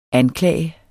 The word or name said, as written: anklage